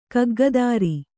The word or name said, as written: ಕಗ್ಗದಾರಿ